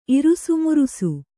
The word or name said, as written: ಇರಿಸುಮುರಿಸು